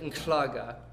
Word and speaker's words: Nhlaga: Where one of the guys we met, his name was spelled N-H-L-A-G-A. And so you'd pronounce it Nklaga, which is, and that's a common consonant cluster is H-L.